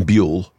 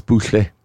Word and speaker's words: Buhle: But his surname is spelled B-U-H-L-E, so I thought it's Buchle. – It happens sometimes. – It might be. – No, it happens. – I wish you could have seen Tim from Egg Chasers. – Yes. – Face. Is it Jordan Buchle? He says, no, Buell.